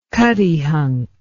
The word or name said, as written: Cuttyhunk